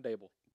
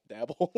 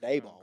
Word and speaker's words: Daboll: I've been saying it wrong then. – How have you been saying it? – Dabble? – I think it's Daboll, Daboll, something like that. – Brian Daboll. – It's a long A.